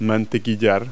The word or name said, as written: butt